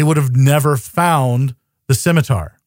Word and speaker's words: scimitar: Scimitar. – Hey, I'm thinking if Podcrawl lady scimitar. And Riker both pronounce it like that, that's the pronunciation.